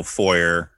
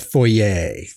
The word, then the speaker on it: foyer: The three of them are sitting in this little foyer, waiting to go into this meeting. Like you said, I think they pronounce that foyer. – Foyer. – Yeah. – Okay. The foyer.